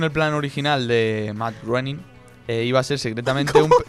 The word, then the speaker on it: Groening: Running. – ¡Qué internacionales estamos hoy! – Por favor. – Me encanta. – Si no sabes pronunciar, no me digas. – Es Groening. Groening.